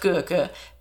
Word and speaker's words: Gurke: So Marco Gurke, his name should be pronounced Gurke, but it's probably Americanized like Gekka or something.